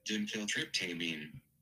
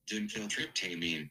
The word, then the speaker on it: Dimethyltryptamine: Dimethyltryptamine. – What? Dill? – Dimethyltryptamine. – My man said it. Dime. Oh, it's duh. Dimethyltryptamine.